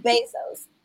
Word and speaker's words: Bezos: Is it Bezos or Bezos? I always think about Bezos. – I knew, as soon as I said it, I'm like, I ain't saying it right. But anyway, y'all knew what I was talking about. Jeff Bezos. Now I got to concentrate so I say correctly. Jeff Bezos.